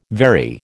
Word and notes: vary: American English

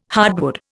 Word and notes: hardwood: Indian

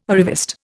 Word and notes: arriviste: Indian

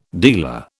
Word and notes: dealer: British English

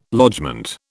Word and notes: lodgement: British English

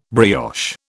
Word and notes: brioche: British English

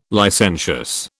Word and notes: licentious: British English

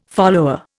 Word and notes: follower: Indian

